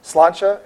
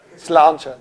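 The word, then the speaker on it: Sláinte: We meet at Slancha in Fells Point on the second Thursday of Slancha, S-L-A-I-N-T-E, in Fells Point. I try to pronounce it right in case anybody knows Gaelic. I don't know. What's that? – Slancha. – There you go.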